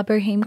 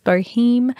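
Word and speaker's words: Bohème: in those first few years, I was also running another business that I started about a year after CJCC called La Boheme Collective. I actually think that that word is pronounced Bohem. I did not know that at the time, and it stuck in my brain as Boheme forevermore.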